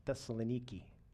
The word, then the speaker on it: Thessaloniki: Today on the map, if you find it, it's in a place in Greece pronounced Thessaloniki.